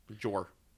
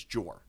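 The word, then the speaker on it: Jorah: Fucking J.R. Mormont, though. – Jor. – Looks like J.R. – It's Jor.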